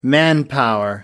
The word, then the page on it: manpower: American English